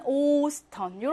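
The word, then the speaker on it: Ostern: 에스테 두 개 나오지만 오스턴 이렇게 발음해야 합니다.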